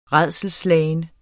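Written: RÆDSELSSLAGEN

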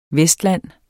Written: vestland

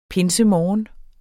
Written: pinsemorgen

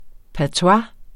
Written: patois

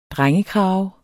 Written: drengekrave

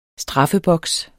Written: straffeboks

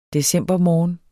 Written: decembermorgen

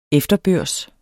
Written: efterbørs

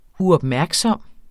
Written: uopmærksom

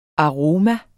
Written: aroma

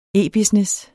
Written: e-business

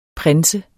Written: prins

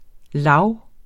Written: lag

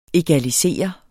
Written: egalisere

